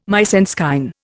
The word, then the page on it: mise en scène: Indian